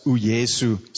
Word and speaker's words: uYesu: In Xhosa, it's uYesu. So it's just the J that's pronounced differently.